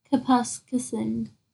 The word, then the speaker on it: Kapuskasing: Kapuskasing? Right there. – Kapuskasing.